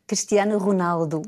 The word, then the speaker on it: Cristiano Ronaldo: Cristiano Ronaldo.